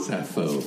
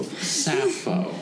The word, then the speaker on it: Sappho: Suffering Sappho. Natch. Did I say that wrong? Because I really don't know. – Sappho. – Sappho.